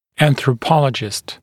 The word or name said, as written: anthropologist